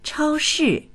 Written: chao1shi4

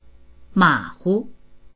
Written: ma3hu

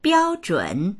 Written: biao1zhun3

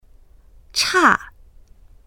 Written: cha4

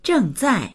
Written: zheng4zai4

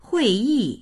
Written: hui4yi4

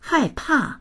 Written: hai4pa4

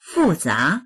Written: fu4za2

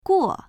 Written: guo4

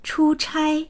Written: chu1chai1

